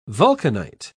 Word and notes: vulcanite: British English

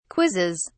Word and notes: quizzes: British English

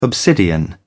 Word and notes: obsidian: British English

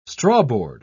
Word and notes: strawboard: British English

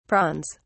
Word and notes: prawns: British English

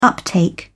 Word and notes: uptake: British English

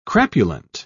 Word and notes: crapulent: British English

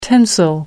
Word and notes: tensile: American English

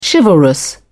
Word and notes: chivalrous: British English

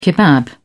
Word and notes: kebab: British English

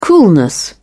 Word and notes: coolness: British English

